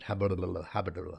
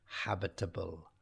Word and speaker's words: habitable: And that word habitable, quite difficult. If you say it fast, there's a tendency to trip over it. Habitable. Yeah? So you have to make sure that you pronounce it habitable.